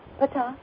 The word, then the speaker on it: Ptah: I've been thinking about Ptah, you know, how do you say that? Ptah? – Ptah.